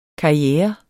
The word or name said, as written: KARRIERES